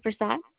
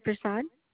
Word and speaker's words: Persaud: My first last name is Sharda Prasad. And is this for I'm so sorry. Say this name again, please. – Sorry. Sharda Prasad. – How do you spell that last name, please? – P-E-R-S-A-U-D.